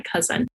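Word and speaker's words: cozen: It's C-O-Z-E-N. I always wanted to say cousin whenever I said it, but it actually is pronounced like cousin, and it means to cheat, deceive, or trick.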